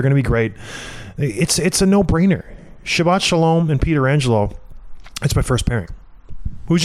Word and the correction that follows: Petrangelo: My second pairing is Petrangelo. That's how you say it.